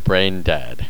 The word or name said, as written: brain dead